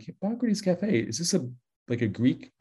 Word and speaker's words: Hippocrates: Trouble is, most of the public does not know how to pronounce Hippocrates. And if you were to guess how they mispronounce it, if you think it's hypocrites, you are correct.